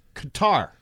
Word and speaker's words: Qatar: The official pronunciation is Qatar.